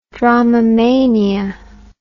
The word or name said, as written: dromomania